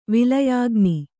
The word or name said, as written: ವಿಲಯಾಗ್ನಿ